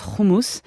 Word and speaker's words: houmous: Alors déjà, ça se prononce houmous, car en hébreu comme en arabe, il y a le H et ça se prononce R.